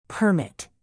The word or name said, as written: permit